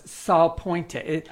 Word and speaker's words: Salpointe: Did I pronounce that right? Because it didn't sound like I did. – It's Catholic. It's LaSalle, though. Did you say LaSalle? – No, it's Salpointe. S-A-L-P-O-I-N-T-E.